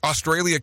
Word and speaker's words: Australia: The word Australia contains three A's. Each of them is pronounced differently.